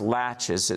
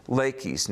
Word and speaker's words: Laches: Now, it's spelled like latches, L-A-C-H-E-S. But it's not pronounced latches, it's pronounced Laches.